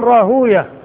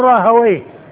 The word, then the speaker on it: راهويه: والمحدثون ينطقون بها بالواو الساكنة بعدها ياء مفتوحة وآخرها هاء واو ساكنة مضموم ما قبلها وأما أهل اللغة فينطقونها مفتوحة الواو والياء ساكنة وهي المختومة بويه مختومة بويه المحدثون يقولون راهوية واللغة يقول راهويه